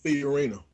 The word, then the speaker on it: Fiorina: So the goal of really the bottom people and what the um, Carly uh, Fiorona, uh, I, am I'm, I'm totally mispronouncing her name. – Fiorina. – Yeah, Fiorina. Thank you.